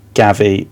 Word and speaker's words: Gavi: Not Xavi, the manager. Gavi with a G, I think that's how it's pronounced. – Yeah, it is, yeah.